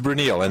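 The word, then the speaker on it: Bruyneel: Am I pronouncing it right? Is it Bruneel or Brunel? – It's Bruneel, and for the Americans, sometimes it's difficult, but I congratulate you with the pronunciation of my name.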